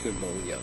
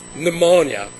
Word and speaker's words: pneumonia: pneumonia because the P doesn't need to be pronounced, pneumonia in bovine.